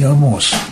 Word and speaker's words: Амос: Но дело в том, что для Богдана Хмельницкого что еврей, что Ян Амос Коменский, это было примерно одно и то же. Я, кстати, извините, говорю «Амос», а не «Амос». Чехи произносят «Амос», у них ударение скатывается на последний слог, но я уж посвящен написанию.